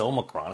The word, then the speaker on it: Omicron: And by the way, that is how it's pronounced. It is Omicron. It's not Omicron or anything else.